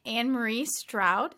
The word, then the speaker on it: Anne Marie Stroud: Today we have a special guest for you all, Anne Marie Stroud.